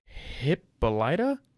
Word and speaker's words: Hippolyta: Vance, leaves wife Hippolyta? Hippo, what is that name? – Hippo? – It's literally Hippo, H-I-P-P-O-L-Y-T-A, one word.